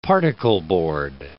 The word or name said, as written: particleboard